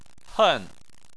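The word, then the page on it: hen: tone 4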